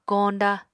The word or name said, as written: koñda